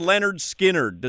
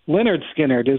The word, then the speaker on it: Lynyrd Skynyrd: Brian Windhorst, real quickly, the rock band Lynyrd Skynyrd, does that name rhyme? – Lynyrd Skynyrd, isn't it? – Yeah, Lynyrd Skynyrd.